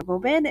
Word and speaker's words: goben: Esta palabra goben es una palabra aguda si se acentúa en la última sílaba debido a que esta termina en n.